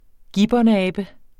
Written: gibbonabe

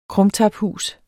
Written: krumtaphus

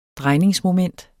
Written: DREJNINGERNES

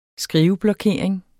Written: skriveblokering

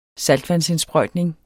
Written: saltvandsindsprøjtning

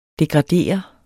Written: DEGRADEREDES

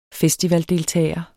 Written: FESTIVALDELTAGER

